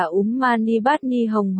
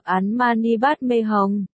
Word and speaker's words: Úm Mani Bát Ni Hồng: Có thể dịch câu này là Om, ngọc quý trong hoa sen, Hum, theo âm Hán, Việt, câu này được đọc là Úm Mani Bát Ni Hồng hoặc Án Mani Bát Mê Hồng.